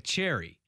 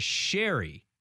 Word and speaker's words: Cheri: So her name is spelled C-H-E-R-I. So it kind of looks like Cherry. – Cherry, yeah. – But she's always introduced herself as Sherry.